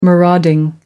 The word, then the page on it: marauding: American English